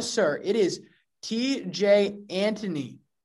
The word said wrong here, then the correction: Antone: I wish you could say his name right. TJ Antone.